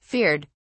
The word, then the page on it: feared: British English